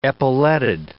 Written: epauletted

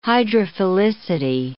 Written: hydrophilicity

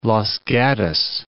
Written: Los Gatos

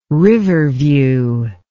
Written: riverview

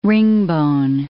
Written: ringbone

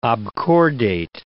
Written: obcordate

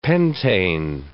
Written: pentane

